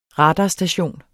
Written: radarstation

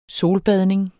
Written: solbadning

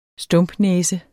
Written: stumpnæse

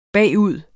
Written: bagud

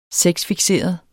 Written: sexfikserede

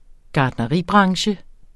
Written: gartneribranche

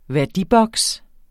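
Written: VÆRDIBOKS

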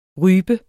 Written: rype

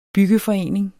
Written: byggeforening

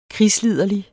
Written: krigsliderlig

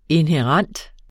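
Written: INHÆRENT

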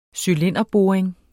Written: cylinderboring

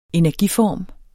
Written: energiform

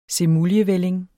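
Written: semuljevælling

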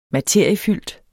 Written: materiefyldt

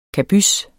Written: kabys

